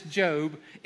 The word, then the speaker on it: Job: Okay, if you're new to the Bible, we pronounce this Job. In America, we would say job, okay? And so some of you are afflicted with your job, but this is really Job, okay? This is Job.